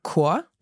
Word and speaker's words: Chor: There are some words where you pronounce it as a K. Mm-hmm. So, for example, der Chor.